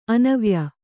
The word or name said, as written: ಅನ್ವಯ